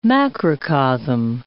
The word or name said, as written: macrocosm